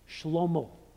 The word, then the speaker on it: Shlomo: If we were to pronounce his name in the Hebrew, I don't know exactly what it would sound like, but it would sound something like Shlomo, which sounds like maybe the only Hebrew word that you know, Shalom.